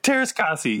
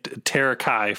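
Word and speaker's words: Teräs Käsi: Kira does some, what's the official pronunciation of it now? – Tereskasi, right? – Tereskasi. Yeah, I've been saying Terakai for the last 25 years.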